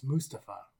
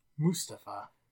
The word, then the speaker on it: Mustafa: Nope, it's not Mustafa. It's Mustafa. Which I thought the commentators were saying it wrong, but no, he fucking said his own name. Mustafa.